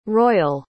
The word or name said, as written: royall